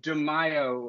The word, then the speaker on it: Di Maio: Um, I, I did bring up Di Maio.